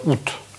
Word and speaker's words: août: Просто ут.